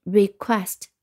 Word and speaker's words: request: 那你会发现这两个音节重音是在第二音节的，所以是 request，re r e quest q u e s t，所以我们再来拼一遍 request r e q u e s t 啊，注意 q u 的发音 q 和后面的 e s t 拼成 quest。